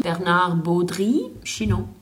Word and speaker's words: Bernard Baudry Chinon: Bernard Baudry Chinon.